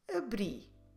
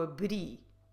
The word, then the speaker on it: abri: if a word ends in I or U, or in Portuguese I, U, we should stress the last syllable. And that explains why we say eu abri. Eu abri.